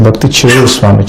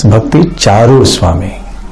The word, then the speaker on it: Bhakti Charu: For Bhakti Charu Maharaj, they say Bhakti Chiru Swami, Chiru. It's a Chiru, it's not, it's Bhakti Charu Swami.